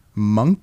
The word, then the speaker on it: Munck: M-U-N-C-K. How would you pronounce that? – C-K. Monk? – Monk.